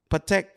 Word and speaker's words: Patek: How's it pronounced? Patek? – Patek, yeah.